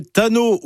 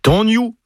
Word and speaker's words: Tanyou: Donc la bonne prononciation c'est Tanyou, des feux, devenu en français Tano ou Tanyou.